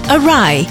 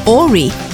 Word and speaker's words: awry: It's pronounced awry, not awry.